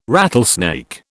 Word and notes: rattlesnake: British English

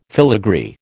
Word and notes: filagree: American English

